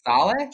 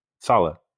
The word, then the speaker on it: Saleh: So Robert's, how do you say his last name anyway? – Saleh. – Oh, okay. – Saleh.